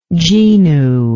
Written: genu

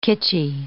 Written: kitschy

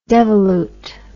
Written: devolute

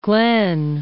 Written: Glenn

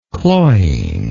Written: cloyingness

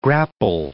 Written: grapple

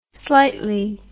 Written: slightly

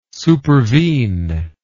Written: supervene

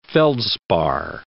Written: feldspar

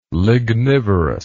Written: lignivorous

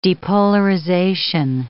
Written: depolarization

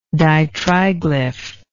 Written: ditriglyphic

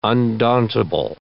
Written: undauntable

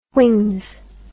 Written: whings

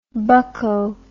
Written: bucco

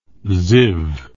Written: ziv

